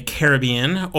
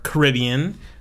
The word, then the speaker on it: Caribbean: So, unlike my most recent episodes, which have all been concentrated in the Caribbean or Caribbean, it's always pronounced both ways. I actually pronounce it both ways, depending on my mood.